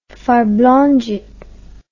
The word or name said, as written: farblondjet